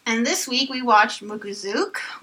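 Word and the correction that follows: Mukōzuke: Mukuzuke, which first aired March 28, 2014.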